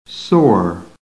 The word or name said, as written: SORE